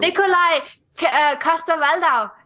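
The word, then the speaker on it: Nikolaj Coster-Waldau: I like that actor too, Nicolas Castro. – Nicolai Coster-Waldau. And yeah. – He's Danish. – Woo! Welcome to Sweden. – It's funny because I just butchered his name, and she just like so perfectly said his name.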